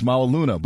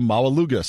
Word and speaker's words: Maualuga: And I asked him, and he said it's Maualuna, but everybody called him Maualuga.